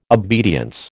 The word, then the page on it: obedience: American English